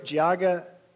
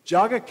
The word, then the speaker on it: Geauga: Last Monday, I traveled up with my sister and brother-in-law and two nephews to the great Geauga, did I say that right? Geauga, that's always a struggle. Geauga County Fair in Burton, Ohio.